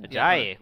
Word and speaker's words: Ajayi: Ajayi, which a lot of people thought was going to go in the second round.